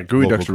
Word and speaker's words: geoduck: Yeah, gooey ducks are real slow. – They're not quick. Yeah. They have proboscises that stick out of the mud. – A gooey duck. – It's spelled geoduck.